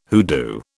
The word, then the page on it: hoodoo: British English